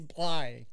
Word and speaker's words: Bly: It's like Fly, but Bly. A lot of people say his name Place, which is not correct.